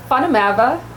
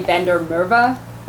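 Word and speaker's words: Van der Merwe: Natasha Fanamava is how you say it in, I'm South African, so, and it's an Afrikaans, it's the most common Afrikaans name. And then, it's like Smith, there were jokes about us. Fanamava walked into a bar. And here in America, we just say Vander Merwe.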